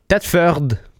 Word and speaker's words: Thetford: Et Thetford, ça aussi, c'est une autre ville qu'on prononce différemment. C'est Thetford.